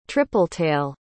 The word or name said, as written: tripletail